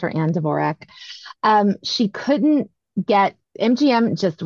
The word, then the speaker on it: Dvorak: It is not pronounced, you know, maybe she wanted it pronounced Dvorak at one time, but very quickly Dvorak became the common pronunciation. So she was always Anne Dvorak. So somebody found some obscure quote that they put on Wikipedia 20 years ago. And so everybody always has to say it's pronounced Dvorak.